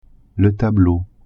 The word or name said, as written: tableau